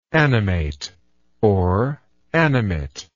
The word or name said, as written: animatingly